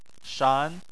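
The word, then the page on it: shan: tone 2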